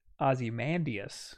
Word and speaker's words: Ozymandias: Yeah, we always say Ozymandias. – Yes. – Because that just makes sense. – Because that's how it's spelled in Watchmen.